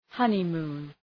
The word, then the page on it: honeymoon: ‘hʌnı,mu:n